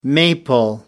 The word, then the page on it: maple: American English